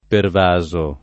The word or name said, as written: pervaso